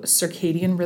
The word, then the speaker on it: circadian: I've heard a lot about circadian rhythms.